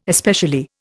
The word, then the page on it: especially: Indian